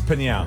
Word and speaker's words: Pinal: Is it Pinal? – It's Pinal. – Pinal. – Pinal. – Okay, there we go. – Pinal. It's like an onomatopoeia.